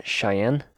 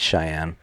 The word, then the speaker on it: Cheyenne: Cheyenne? – I think it's Cheyenne. – So they're another group of Sioux. – Like the capital of Wyoming, I think.